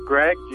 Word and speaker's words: Greg: It's Greg, G-R-E-G.